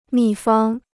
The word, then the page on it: 秘方: mì fāng